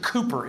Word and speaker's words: Cowper: They pronounce it Cooper in England.